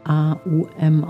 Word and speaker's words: Om: Das Om-Mantra wird allerdings A-U-M ausgesprochen. Also man teilt das so ein bisschen auf. Erst kommt das A, wie ein sanftes A. Dann geht es über ein offenes U.